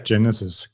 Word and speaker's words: Genesis: Kann jeder machen, wie er will. Also ich sage Genesis. Klingt irgendwie cooler.